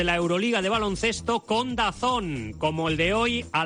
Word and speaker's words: DAZN: DAZN se pronuncia DAZN.